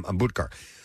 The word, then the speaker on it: Ambudkar: That's how you say his name, Ambudkar.